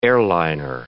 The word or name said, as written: airliner